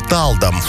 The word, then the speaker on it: Талдом: Подмосковный город удивляет туристов уже одним названием с непривычным поначалу ударением на первый слог. Отправляемся в Талдом.